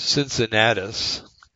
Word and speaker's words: Cincinnatus: This guy's name is Cincinnatus, spelled like Cincinnati, but Cincinnatus.